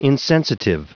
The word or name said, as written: insensitive